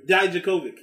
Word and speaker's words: Dijakovic: Dominic Dokovic, or how you say his name? – Dominic Dijakovic.